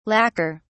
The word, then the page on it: lacker: British English